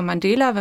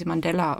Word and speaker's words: Mandela: Ja, der Name Mandela oder, also ich glaube, wir in Deutschland sagen immer Mandela, wenn man sich aber Videos anschaut, internationale Videos, also eigentlich werden sie Mandela ausgesprochen. Und der kommt natürlich von Nelson Mandela, den wir alle kennen, der Friedensnobelpreisträger und erste schwarze Präsident von Südafrika.